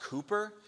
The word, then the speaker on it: Cowper: His, I don't know if anybody can actually read cursive nowadays, so we don't really teach it, but it looks like Cowper, but it's actually pronounced Cooper.